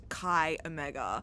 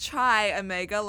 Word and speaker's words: Chi Omega: Chi Omega. It is not Chi Omega like a chai latte. It is actually Chi Omega.